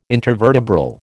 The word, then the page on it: intervertebral: American English